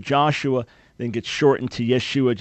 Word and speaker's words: Yeshua: So the proper way that the Hebrew or Aramaic name of Jesus was originally was Yeshua. That would have been the right pronunciation, Yeshua. If you want to get technical, Yeshua with an ion at the end. But the important part is the Yeshua.